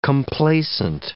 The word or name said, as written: complaisant